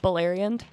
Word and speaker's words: Beleriand: Beleriand.